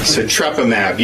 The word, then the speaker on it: Sotrovimab: How do you pronounce it? Sotrovimab. Yeah, I always mess that up.